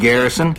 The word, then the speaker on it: Garrison: It's G-A-R-R-I-S-O-N. Just like it sounds.